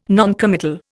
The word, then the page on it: noncommittal: Indian